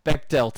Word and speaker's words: Bechdel: you're applying the bed bed shell test. I can't fucking pronounce that. Bechdel test.